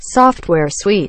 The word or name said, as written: software suite